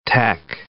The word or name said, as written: tech